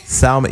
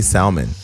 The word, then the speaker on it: salmon: Salmon. You don't say salmon. Look, it's salmon. The L is silent.